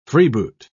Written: freeboot

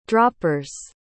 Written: droppers

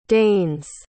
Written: deigns

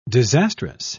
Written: disastrous

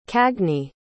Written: cagney

